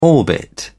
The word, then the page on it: orbit: British English